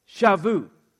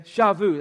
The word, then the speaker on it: Shavuot: They call it Shavuot. Okay? Shavuot. That's pronounced like, or spelled out like this.